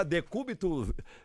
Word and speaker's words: decúbito: O côncavo e o convexo, o decubo, decubo o quê? Como é que fala? Decúbito, como é que fala? Decúbito, é negócio de... Fátima atrapalhou o negócio todinho aqui, viu? Decúbito dorsal, rapaz, decúbito dorsal.